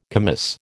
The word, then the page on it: commis: American English